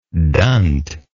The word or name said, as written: dunt